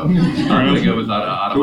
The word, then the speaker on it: Automata: Am I saying that right? – Automata, I would have said.